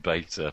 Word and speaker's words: beta: I'm afraid it's pronounced beta, but there you go.